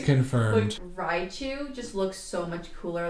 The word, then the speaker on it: Raichu: But may I say, Raichu is way better to look at. – I think it's pronounced Raichu.